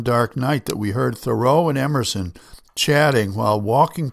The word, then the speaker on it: Thoreau: For 11 years, our family lived in his hometown of Concord, Massachusetts, where they pronounced his name Thoreau, not Thoreau.